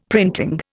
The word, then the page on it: printing: Indian